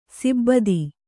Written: ಸಿಬ್ಬತಿ

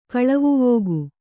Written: ಕಳವುವೋಗು

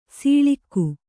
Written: ಸೊಳಗ

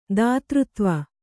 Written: ದಾತೃತ್ವ

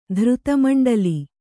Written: ಧೃತಮಂಡಲಿ

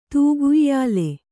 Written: ತೂಗುಯ್ಯಾಲೆ

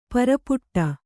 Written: ಪರ್ಪಟಿ

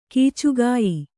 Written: ಕೀಚುಗಾಯಿ

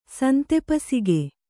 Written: ಸಂತೆಪಸಿಗೆ

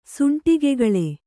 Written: ಸೂಂಟಗೆಗೊಳ್

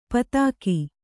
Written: ಪಥಕ

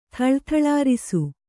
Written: ಥಳಥಳಾರಿಸು

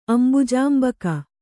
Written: ಅಂಬುಜಾಂಬಕ

